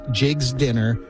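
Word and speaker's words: Jiggs dinner: People saying, yes, I did pronounce it right. It is Jig's Dinner.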